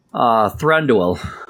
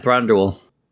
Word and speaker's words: Thranduil: Thranduil. – Thranduil? – Yeah, Thranduil.